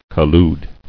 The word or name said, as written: Colluded